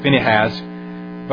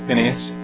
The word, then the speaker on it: Phinehas: Some people pronounce that Phinehas, but I like to say Phinehas, who was one of the children of Israel.